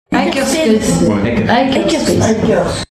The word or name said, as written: Hackerspace